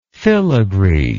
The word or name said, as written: fillagree